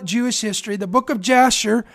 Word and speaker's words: Jasher: Again, this is found in the book of Jasher. It's pronounced Yasher in Hebrew, and this is a book of Jewish history.